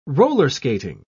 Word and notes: roller-skating: British English

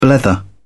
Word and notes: blether: British English

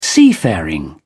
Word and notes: seafaring: British English

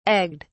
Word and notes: egged: British English

